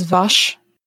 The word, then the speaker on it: Vash: My bat's name is Vash, which is actually Wash, like from Firefly, but they can't pronounce it, so it's Vash.